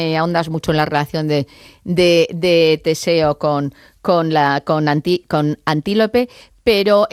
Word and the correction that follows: Antíope: me gustaría no sé, lo he dicho mal el nombre, es Antíope.